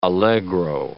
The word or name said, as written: allegro